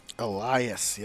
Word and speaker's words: Elias: Elias, yes. There you go. – Elias. – So Donna is the only one you fuck up. All right, go ahead. – Elias going to Raw.